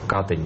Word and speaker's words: Катынь: О роли Меньшагина в свидетельствовании Катынского процесса, Катынь, как сейчас нам говорят правильно, это заставить ударение, рассказывает историк, глава польской комиссии правозащитного центра «Мемориал» Александр Гурьянов.